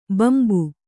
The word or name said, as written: ಭಂಭಾ